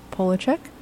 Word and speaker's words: Polachek: Caroline Polachek? – Yes.